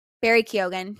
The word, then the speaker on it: Keoghan: Barry Keoghan.